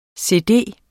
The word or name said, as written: cd